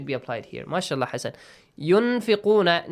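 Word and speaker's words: Yunfiquna (ينفقون): Yunfiquna. – Yun. Nun shouldn't be pronounced clearly. We should hide the sound of nun.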